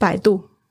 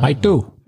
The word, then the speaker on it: Baidu: What how do you say, how do you say that? – Baidu. – It's like a little paw, like a dog paw. – Ah. Baidu.